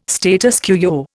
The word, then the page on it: status quo: Indian